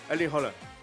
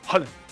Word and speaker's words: Haaland: Y es rápido, es Erling Haaland. – Haaland.